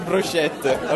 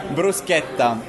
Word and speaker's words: bruschetta: It's not bruschetta, okay? Bruschetta. – Bruschetta.